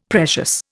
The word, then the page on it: precious: Indian